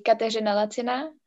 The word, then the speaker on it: Kateřina Laciná: In Czech it would be Kateřina Laciná, but for internationals it's Katerina Laciná.